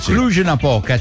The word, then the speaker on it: Cluj-Napoca: Di stretching a questo punto, perché a Cluj-Napoca. Si pronuncia così. Cluj-Napoca, città della Romania, c'è un modo molto semplice per viaggiare gratis sugli autobus, e cioè fare gli squat.